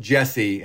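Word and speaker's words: Djesse: Jesse is pronounced Jesse, and it's a reference to his initials, J-C. The D is silent.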